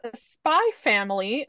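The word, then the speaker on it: Spy×Family: All right, this week I have Spy Family, Volume 10. It's by Story and Art is by Tatsuya Endo. Incidentally, I Googled it and apparently you don't pronounce the X. It's just there for aesthetics, but I kind of like it.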